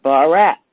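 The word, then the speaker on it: Barak (ברך): B-A-R, be like boy, A-R-A-K. And it is pronounced Barak, is the Hebrew pronunciation for the word blessed and the past tense word blessed.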